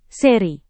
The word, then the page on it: seri: Indonesian